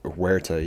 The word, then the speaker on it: Tenoch Huerta: Tenoch Huerta? – Huerta, yeah.